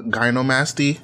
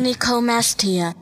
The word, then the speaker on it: gynecomastia: gynecomastia.